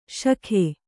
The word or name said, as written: ಷಕ್ಕು